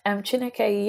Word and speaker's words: Chineke: And I guess that would be the same with the work you're doing with the Chineke Orchestra. Have I pronounced that correctly? – Chineke, yeah. Chineke.